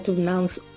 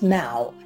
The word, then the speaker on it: now: You hear them say noun. We want to say this. No, it's now.